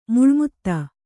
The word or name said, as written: ಮೇಲ್ಮಿತಿ